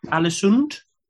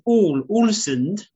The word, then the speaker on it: Ålesund: Is this Alesund? – It's Ålesund. – Ålesund.